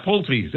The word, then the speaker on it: Pulte: Can you tell us who the hell Bill Pulte is, if I'm even saying that right? – Yeah, Pulte.